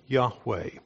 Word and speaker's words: Yahweh: And the Jewish people had lost the pronunciation of God's name, Yahweh. We think that's how it should be pronounced.